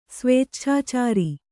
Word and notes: ಸ್ವೇಚ್ಛಾಚಾರ: svēcchācāra

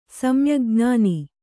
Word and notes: ಸಮ್ಯಗ್ಜ್ಞಾನಿ: samyagjñāni

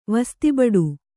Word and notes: ವಸ್ತಿಬಡು: kannada

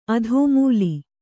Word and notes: ಆದಿಮೂಲ: kannada

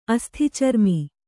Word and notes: ಅಸ್ಥಿಚರ್ಮಿ: asthicarmi

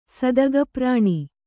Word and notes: ಸದಗಪ್ರಾಣಿ: kannada

